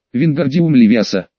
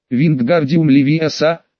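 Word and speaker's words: Вингардиум Левиоса: «Надо произносить так. Вингардиум Левиаса, в слоге «гар» должна быть длинная «а».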